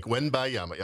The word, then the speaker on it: Wembanyama: Oh, Victor Wembanyama? – I call him Victor. – Did I say that right? Wembanyama? – Wembanyama. – Yes, you did, I think. Wembanyama, yep.